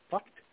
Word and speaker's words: FUCT: the name F-U-C-T, mm-hmm. – Which I guess is pronounced fucked.